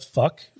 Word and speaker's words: Phoque: And in this one, they introduced the local strip club, which is called Club Fuck, but it's spelled P H O Q U E is how it's spelled.